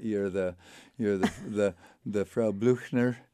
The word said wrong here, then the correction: Blücher: Blücher. – Sorry. And one does not dare say it wrong to a German, right?